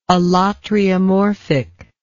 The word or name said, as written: allotriomorphic